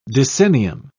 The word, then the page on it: decennium: British English